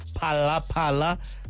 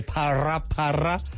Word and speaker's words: Pala Pala: Not to mention the different variations of pronunciations. Pala Pala. I've heard people say para para. I've heard people do like a silent or subtle roll of the tongue where you're not sure if it's an R or an H, like para para.